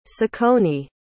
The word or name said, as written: ciccone